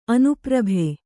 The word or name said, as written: ಅನುಪ್ರಭೆ